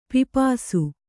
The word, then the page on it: ಪಿಪಾಸೆ: pipāse